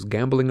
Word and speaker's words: gamboling: It's not gambling like... – Gamble. – Yeah, it's pronounced the same way as gambling, but G-A-M-B-O-L-I-N-G.